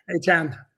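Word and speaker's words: Achane: Grant Albury says, Mostert is good, but he isn't a workhorse and sorely needs A-chain. Did I say it right? – Yeah. A-chan.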